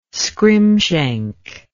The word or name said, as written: scrimshank